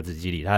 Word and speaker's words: 都路里: 对，就是紫吉里，中文我也不知道那个叫什么，对，中文有人就直接念十里，对对对对对。可是那一家是京都很有名的一个，就是百年的一个茶庄，然后它等于是说它有一个比较高的茶铺，它那另外它还有一个叫茶寮都路里的，它发音也叫紫吉里，它专门做给年轻人的，或者是抹茶冰啦、抹茶冰淇淋。